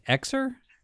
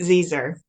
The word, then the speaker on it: xyr: Is it, do you pronounce it like Xer? – Zer. So there's Z and Zer.